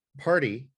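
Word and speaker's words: Pardé: He launched a nail polish brand called Party Brewing. It's spelled P-A-R-D-E, but it's pronounced Party, P-A-R-T-Y.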